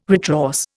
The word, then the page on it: retrorse: Indian